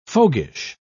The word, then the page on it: fogyish: British English